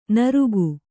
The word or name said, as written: ನಾರಕ